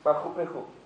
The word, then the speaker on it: Machu Picchu: Machu Picchu. Membacanya begitu ya, betul ya. Machu Picchu.